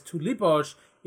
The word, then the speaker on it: tulipoj: But why it is tulipoj is you always put the accent on the second syllable.